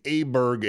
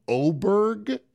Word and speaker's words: Åberg: I hear it's now Åberg is how they're pronouncing it. – They almost said they don't pronounce the G either. Åberg. – Åberg is what I heard.